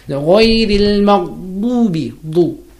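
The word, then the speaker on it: Maghdubi (مغضوبِ): It's not maghdubi. Maghdubi. – Maghdubi. – Maghdubi. Alayhim. Alayhim. Alayhim. – Him. – Walad. And then door coming. – Yeah. – Ghayril maghdubi alayhim walad dallin.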